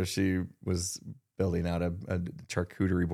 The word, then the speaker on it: charcuterie: And she's into charcuterie. Am I saying that right? – Charcuterie. Something like that.